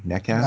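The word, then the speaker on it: Nečas: Is that how you pronounce it? Netchass? – I believe so.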